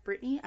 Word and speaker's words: Britni: Myself, my Twitter, and Instagram are the same name, at MJ underscore S-A-N-T-I-I. MJ Santi, guys. Brittany? – You can find me on Twitter at Brittany underscore Lynn, that is B-R-I-T-N-I underscore L-Y-N-N-E, and on Instagram it is spelled the same way, it is Brittany Lynn without the underscore.